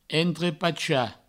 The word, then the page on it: entrepachar: entrepacha; provençal